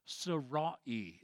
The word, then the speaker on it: Sarai: Sarai is how it was originally pronounced.